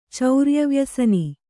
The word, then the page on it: ಚೌರ್ಯವ್ಯಸನಿ: Kannada